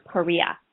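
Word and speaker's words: Parrilla: Lana Parilla is fantastic. – I think it's Perea.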